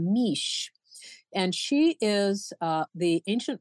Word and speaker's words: Mis: M-I-S, but it's pronounced Mish.